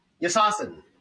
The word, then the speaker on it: Yassasin: How do you pronounce this? Yassasin? – Yassasin! Yassasin. – Turkish for long live.